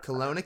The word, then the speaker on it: Kelowna: Kelowna, Canada, yeah.